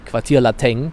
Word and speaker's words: Quartier Lateng: Und dann aber in der kölschen Aussprache quasi, Quartier Lateng, daraus geworden, weil es ähnlich wie das Quartier Latin in der Uni liegt, ne?